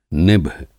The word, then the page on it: निभ: Hindi